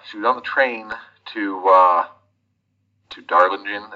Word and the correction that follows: Darjeeling: Is that how you pronounce Darjeeling. it? – Darjeeling. Darjeeling. Okay.